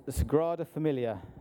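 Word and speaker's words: Sagrada Família: Sagrada Familia.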